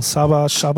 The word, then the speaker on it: Csaba: Zum Beispiel dann aber auch, und jetzt geht's wieder los, Shaba heißt der, glaube ich, ne? C-S-A-B-A. – Da sind wir wieder. Wie wird's ausgesprochen? – Shaba Ranks.